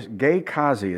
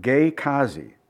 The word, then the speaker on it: Gehazi: And he said to Gehazi, that's how you, Gehazi is how my Bible app pronounced it, Gehazi.